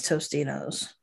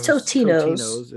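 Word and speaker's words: Totino's: See, I think the pizza is Totinos, and the thing is Tostinos. – No, the pizza. – Tony's? – That's confusing. – Maybe it's because the pizza is Totinos. – Totinos? – See? – But we just say it wrong.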